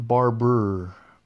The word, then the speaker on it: Barbour: John Barber. It's spelled Barbour.